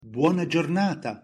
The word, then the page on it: buona giornata: Italian